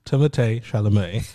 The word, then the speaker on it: Timothée Chalamet: It's Timothée Chalamet. – That's how you say it. – Is that how he says it? – No, that's how you're supposed to say it.